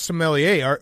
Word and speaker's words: sommelier: You're not a sommelier. How do you say it? – Sommelier. – Sommelier.